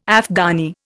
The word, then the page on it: Afghani: Indian